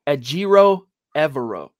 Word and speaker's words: Ejiro Evero: Ejiro Evero.